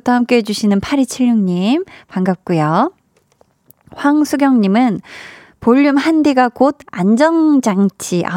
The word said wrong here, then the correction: 안전장치: 아, 나 이 발음 진짜 안 되네, 오늘. 네, 아니, 제가 오늘 오프닝 때도 이 발음이 조금 쉽지가 않았는데, 안전장치.